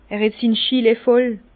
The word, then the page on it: er het sin Schillee voll: Bas-Rhin; Herrlisheim